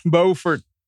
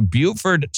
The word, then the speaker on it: Beaufort: I butchered it because there's a Beaufort, South Carolina, and a North Carolina, and they put a different accent on a different syllable, and I tend to kind of goof it up, but that doesn't stop me from throwing a rock.